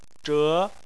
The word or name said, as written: zhe2